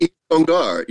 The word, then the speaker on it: Yves Congar: Is it Yves Congar? Is that how you pronounce it? – Yves Congar. Yeah.